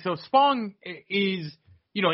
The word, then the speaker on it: Spong: Can you say his name? Spong? – Spong, yeah. – You sure it's not Sponge? – I'm positive, yes.